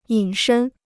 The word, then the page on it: 隐身: yǐn shēn